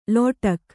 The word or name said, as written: ಲಟಿಕಿ